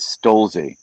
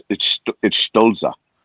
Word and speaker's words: Stolza: It looks like Nicholas Stolze or Stolz, but I want to say it correctly, but it's Stolza.